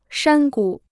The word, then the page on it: 山谷: shān gǔ